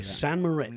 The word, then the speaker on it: St. Moritz: St. Moritz, is that how you say it? – Yeah. – Okay, St. Moritz.